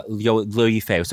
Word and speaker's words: Liu Yifei: Yifei Liu? – Liu Yifei. So Liu is her Liu is her last name. Yeah. But that's so you can the way you said it's perfect.